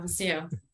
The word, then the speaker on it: WACEO: Is that like, do I pronounce that correct? – Yeah, Waceo.